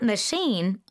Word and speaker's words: machine: 약간 뒤에 좀 길게 빼듯이 발음하는 right. – 거죠? – Machine. – Machine. – Machine. – Machine. – Accent is toward the back.